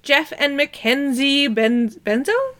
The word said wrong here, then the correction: Bezos: Is that how you pronounce it? – Bezos. – Bezos.